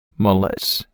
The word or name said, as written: Mullets